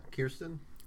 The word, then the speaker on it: Kirsten: Yeah, Kirsten.